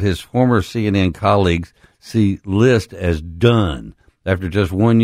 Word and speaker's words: Licht: List, which is L I C H T, but it's pronounced List,